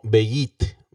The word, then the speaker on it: beyt: Y casa en el antiguo protocananeo se pronunciaba algo así como Beyit, B-E-Y-T.